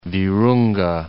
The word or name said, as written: Virunga